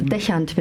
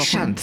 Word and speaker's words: Deschant: Nee, leider nicht. Macht aber nichts. Deschant wird das ausgesprochen. Deschant. Sieht französisch aus, ist es aber nicht.